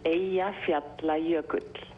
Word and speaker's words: Eyjafjallajökull: The pronunciation is Eyjafjallajökull. – Eyja means island, fjalla means mountain, and jökull means glacier.